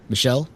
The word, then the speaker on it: Michel: Brandy, is it Mitchell? Michelle? I don't want to pronounce it. – Michelle.